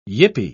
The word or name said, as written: yippie